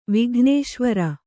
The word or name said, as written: ವಿಘ್ನೇಶ್ವರ